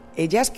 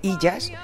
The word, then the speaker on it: Ellas: Ellas, que dice la gente Illas, y no es así. Ellas, a ver, la E, ¿cómo se dice en español? E, ¿no? Pues eso, ese es el título.